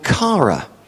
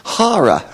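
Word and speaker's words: Hara (χαρά): And she insisted that her name was pronounced Hara. And she said, that's how you pronounce it.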